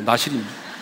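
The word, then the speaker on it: 나실인: 발음을 잘 하셔야 돼요. 마실이 아니고 나시린입니다.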